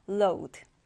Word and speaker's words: load: 자, 오우 발음 해주시면 돼요. load.